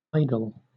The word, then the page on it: idol: British English